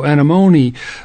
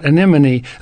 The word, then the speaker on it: anemone: If you look up that genus in a dictionary of plant names, you'll probably see that the accent is on the M-O, so anemone. But, not anemone.